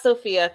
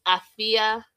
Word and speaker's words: Afia: It's not Sophia. Thank God you didn't hear me right. – Now I'm going to Google what is it born on Friday. And it's Afia. Afia, A-F-I-A. Some people spell it A-F-U-A, Afia.